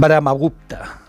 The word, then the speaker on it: Brahmagupta: Brahmagupta lo he dicho bien, mira. – Sí, bastante bien, buena pronunciación india.